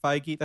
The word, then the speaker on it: Feige: like two or three weeks ago, um, her and Kevin Feige.